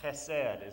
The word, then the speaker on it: Chesed: Chesed is how you pronounce it.